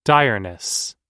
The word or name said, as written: direness